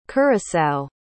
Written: curaçao

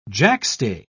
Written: jackstay

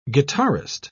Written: guitarist

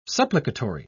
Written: supplicatory

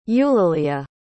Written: eulelia

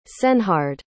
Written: cenehard

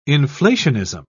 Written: inflationism

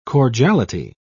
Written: cordiality

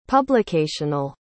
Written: publicational

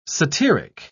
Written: satiric

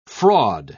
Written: fraud

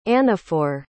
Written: anaphor

